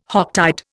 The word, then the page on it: Hocktide: Indian